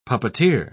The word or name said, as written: puppeteer